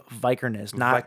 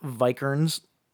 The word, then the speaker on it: Vikernes: How he had tapped Varg Vikernes, which I found out you actually say his name Vikernes. Not Vikernes, like I used to say. – Yeah. – I don't know why I said it that way, but it's Vikernes.